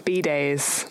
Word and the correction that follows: bidet: Is that how you say it? – I don't know. – Bidet.